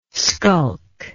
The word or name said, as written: sculk